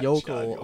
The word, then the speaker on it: yokel: and he's like a stereotypical like slack-jawed yoker. – So slack-jawed yoker. Yokel. Oh my god. – I butchered that. – You had me say yoker too. Yokel.